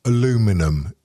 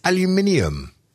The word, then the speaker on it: aluminium: Look, okay, American female, aluminum. You see, Edith, it's aluminium.